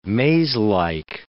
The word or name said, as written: mazelike